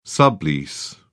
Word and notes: sublease: British English

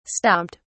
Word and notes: stomped: British English

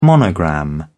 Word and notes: monogram: British English

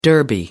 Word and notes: derby: American English

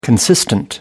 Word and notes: consistent: British English

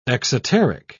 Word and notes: exoteric: British English

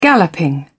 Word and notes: galloping: British English

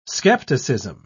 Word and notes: scepticism: British English

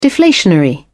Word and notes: deflationary: British English

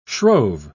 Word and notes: shrove: British English

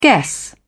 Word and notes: guess: British English

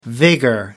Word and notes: vigor: British English

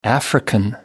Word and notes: african: American English

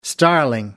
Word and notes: starling: American English

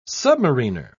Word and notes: submariner: British English

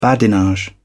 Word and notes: badinage: British English

